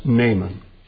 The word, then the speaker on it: Naaman: The Hebrew is Naaman. Naaman, but we know him as Naaman, so I will simply pronounce this man's name as Naaman.